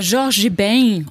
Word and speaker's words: Jorge Ben: Ja, han heter Jorge Ben, om jag ska försöka uttala det så där brasilianskt som gott jag kan.